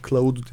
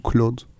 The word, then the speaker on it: Claude: Ama Claude diye okuyor. Onun gerçek okunuşu Claude. Öyle. – Fransızca mı? – Aynen Fransızca.